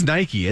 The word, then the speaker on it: Nike: Because it is Nike, isn't it? – It's Nike.